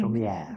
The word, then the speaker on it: Chaumière: Är det så det uttalas? – Mm. Chaumière. Mm. Ja, det är lite franskt ut, Exakt.